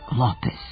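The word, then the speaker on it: López: Mark Lopez. Dakle, akcenat je na prvom slogu.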